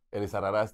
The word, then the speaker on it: Elizarrarás: Elizarraras, te faltó el, acento nada más, te faltó el acento en la A final.